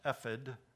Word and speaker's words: ephod: And these are the garments which they shall make: a breastplate, an ephod, or correctly pronounced ephod, but I'm going to say ephod because I'm used to that, a robe, and a skillfully woven tunic, turban, and sash.